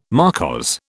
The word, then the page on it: marquise: British English